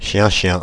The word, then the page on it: chienchien: France (Paris); /ʃjɛ̃.ʃjɛ̃/